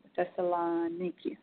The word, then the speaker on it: Thessaloniki: He also has taught in Princeton, Stanford, the University of Rochelle, and the Aristotle University of, let me pronounce it right, sorry if I'm wrong, Thessaloniki.